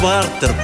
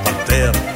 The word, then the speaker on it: партер: А если вы в театр придете, например, вас не пропустят в партер, пожалуйте в партер. Вас не пропустят в партер, пожалуйте в партер.